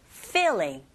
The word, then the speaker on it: feeling: Feeling. Feeling. 우리말처럼 필, 여러분, 필링이 아니라 필링은 벗기는 거예요. feeling. 윗니로 아랫 입술을 살짝 앞에서 뒤로 긁어주시면 되고,